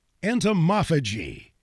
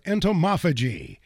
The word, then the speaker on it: Entomophagy: Entomophagy is one of the most mispronounced words of the year. Entomophagy. E-N-T-O-M-O-P-H-A-G-Y.